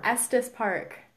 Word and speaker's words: Estes Park: Estes Park. It's like asbestos. You don't want asbestos in your hotel. It's Estes Park.